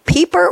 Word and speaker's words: Peper: Anne Rosenberg has sent us a quote from, and Anne, help me pronounce his name. Is it Eliot Peper or Pepper? It's P-E-P-E-R. How do you pronounce his name? – Just like you said at first. It's perfect. – Peper. Okay, good.